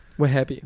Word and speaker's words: Wehbe: But they have a lot of friends who have the surname of Wahhabi. – Wahhabi. – Which is spelt, or most of the time spelt W-E-H-B-E.